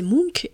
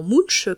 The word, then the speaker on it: Munch: Ce cri dont je vous parlais, c'est celui de Munch, à prononcer Munch et non Munch, comme je l'ai fait pendant des années.